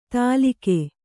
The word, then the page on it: ತಾಲಿಕೆ: tālike